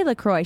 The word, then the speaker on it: LaCroix: A girl at Pure Bar, she said LaCroix, and I was like, I say LaCroix. She's like, yeah, that's how you say it.